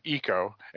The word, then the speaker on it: eco: You said echo instead of eco, and so I was thinking echo, like, you know, your voice bouncing off.